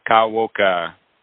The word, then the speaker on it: Kawaoka: Today, I have a special guest, Lane Kawaoka.